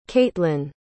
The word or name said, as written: caitlinn